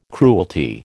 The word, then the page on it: cruelty: American English